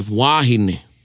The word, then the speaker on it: wāhine: And then of course we have wahine, which means woman, W-O-M-A-N. And then you have wāhine, which means? – Woman. – Woman, W-O-M-E-N.